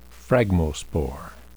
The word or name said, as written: phragmospore